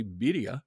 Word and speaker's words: birria: For example, birria, or to pronounce it correctly, birria, which is a spicy meat stew that in central Mexico is generally made of goat.